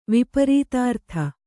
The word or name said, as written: ವಿಪರೀತಾರ್ಥ